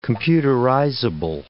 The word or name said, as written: computerizable